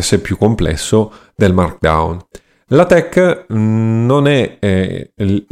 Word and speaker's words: LaTeX: anche se eh, la tech si scrive latex ehm, si legge appunto la tech proprio perché ha questa eh,